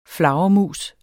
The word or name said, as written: flagermus